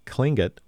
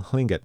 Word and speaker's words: Tlingit: Yedikuka Dion Brady Howard introduced the dance program earlier in the event and gave the crowd a lesson in Tlingit, especially the transition away from the former pronunciation of Tlingit with a hard K sound that doesn't exist in the language.